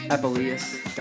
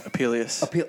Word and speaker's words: Apuleius: Gaius Apuleius Diocles. – I think you said it wrong. Apuleius. – Oh, shit. – Gaius Apuleius. We've been practicing this one.